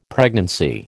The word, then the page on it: pregnancy: American English